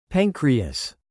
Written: pancreas

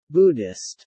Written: Buddhist

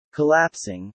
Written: collapsing

